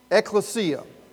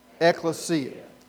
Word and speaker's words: ekklesia: EKKLESIA. One more time. EKKLESIA.